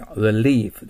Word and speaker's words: relief: 日本語で relief と言うけど、これは l と r の発音が違いがわからないからそう言ってるんです。実際は、 r と言わなきゃいけません。 relief です。